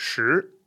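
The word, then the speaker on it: Shi (十): You have to remember that the pronunciation starts with SH, that it's second tone, and that it doesn't have a sort of vowel or rhyming sound in it. You know, other pronunciations that start with SH, like Shan or Shao. They have the An, Ao rhyming sound, but Shi doesn't.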